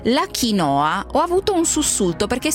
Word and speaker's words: quinoa: Ciao, diciamo subito che l'accento va sulla I. – Allora è quinoa. – Non sulla O come si è abituati in Italia.